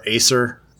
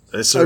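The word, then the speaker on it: Æsir: I can't pronounce that word. A-E, like a weird A-E font looking thing. S-I-R, Acer. – Acer.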